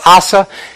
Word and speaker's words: Asa: Or Asa is the proper pronunciation.